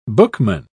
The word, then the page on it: bookman: British English